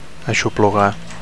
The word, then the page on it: aixoplugar: Catalan